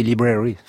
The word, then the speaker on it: Library: On peut les voir à la British Library, mais j'ai pas osé prononcer library. – La British Library. Yes. – Library.